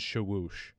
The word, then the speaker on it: Shawish: But guys like Ali Shawoosh, which, by the way, it is Shawoosh, had a very deep discussion about it with his coach the other day. Because it's spelled Shawish, but it's Shawoosh.